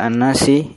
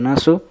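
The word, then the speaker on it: an-nasi (النَّاسِ): Mengapa dibaca an-nasi? Ya, bukan nasa atau nasu. Karena ala huruf jar.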